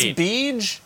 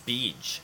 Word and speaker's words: BEEG: You think I pronounce BEEG as in BJ? – No, just BEEG.